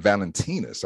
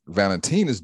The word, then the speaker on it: Valentinus: And it's interesting because, you know, the, you know, this, it was, you know, it was named after a Saint Valentinus. I believe that's how you pronounce his name.